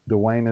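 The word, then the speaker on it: Dwain: You can find me under my name, Dwayne Beatty, on Facebook, Dwayne Beatty Kayak Fishing, or my personal account. Dwayne is spelled funny. It's D-W-A-I-N.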